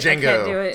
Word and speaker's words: Django: The D is silent. – I can't do it. – Django.